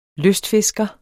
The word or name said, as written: lystfisker